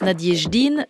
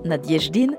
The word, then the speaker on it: Nadejdine: En dix jours seulement, Boris Nadiejdin, je l'avais mal prononcé dans l'épisode précédent, on dit bien Nadiejdin, en dix jours, l'unique candidat opposé à la guerre a été exclu de la course à la présidentielle en Russie.